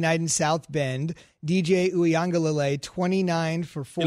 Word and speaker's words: Uiagalelei: So you are in Uiagalelei. – Elite company. – Uiagalelei.